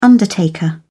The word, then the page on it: undertaker: British English